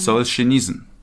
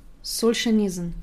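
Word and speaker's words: Solzhenitsyn (Солженицын): Solzhenitsyn. – Solzhenitsyn. Okay. Ich fange nämlich an mit einem Zitat. – Okay. – Das ist nämlich also ein komplett anderer Ansatz, den du hast. Und zwar Alexander Solzhenitsyn.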